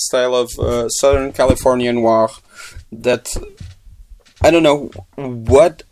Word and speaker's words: noir: I, I always think about film noir, like in the French way. – Yeah, I never say it like that.